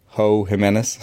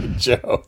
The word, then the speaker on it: Jimenez: And maybe you're, if you're still in full tank mode, maybe you you trade a Ho Jimenez. – That's one of the best mispronounced names in the history of our podcast. – Joe. It's definitely Joe.